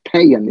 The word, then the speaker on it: paean: I guess it's actually pronounced Paean. It's not pronounced Paean anyway.